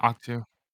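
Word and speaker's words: Ahch-To: So, this then cuts to, and I'm not sure how to, is it Octu that Luke is on? Is that how you pronounce it? – Octu? Yeah, Octu.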